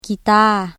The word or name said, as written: ກີຕ້າ